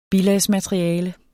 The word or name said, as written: bilagsmateriale